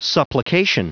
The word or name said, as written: supplication